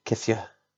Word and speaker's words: Nketiah: Eddie Nketiah. Everyone excited to see Eddie play? – Very excited, and I'm also, having watched the presser yesterday, happy to now pronounce him as Kithia.